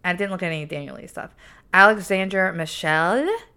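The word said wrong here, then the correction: Alessandro Michele: Is that how you say it? – Alessandro Michelle.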